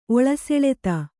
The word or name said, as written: ಒಳಸೆಳೆತ